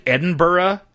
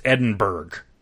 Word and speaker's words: Edinburgh: For, like, it's not like Edinburgh. Edinburgh is not pronounced Edinburgh. It is Edinburgh.